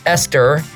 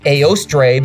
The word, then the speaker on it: Eostre: John thought Esther. I thought Eostre, because again, it's E-O-S-T-R-E. Turns out we're both kind of right. It's Eostre.